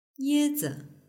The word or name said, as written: yezi